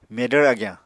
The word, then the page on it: medvr agyañ: Apatani